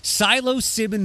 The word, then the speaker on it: psilocybin: They also legalized therapeutic use of psilocybin mushrooms. I'm guessing the P is silent. – Yes. – With a two-year development period, war veterans with PTSD, terminally ill patients, and others suffering from anxiety had voiced support.